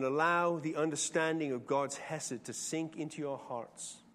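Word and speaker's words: Hesed (חסד): It's the word hesed. Sometimes when you read it in English, it's spelled with a C. C-H-E-S-E-D.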